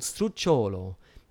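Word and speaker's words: sdrucciolo: And the third line ending is called strucciolo, which means sliding. And the accent's on the third last syllable.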